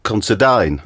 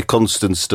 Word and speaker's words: Considine: The correct pronunciation is Paddy Considine. – Yep, that's correct. – Whereas you've been calling him Paddy Constance. Paddy Canongate.